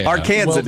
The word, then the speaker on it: Arkansan: Arkansan is the pronunciation.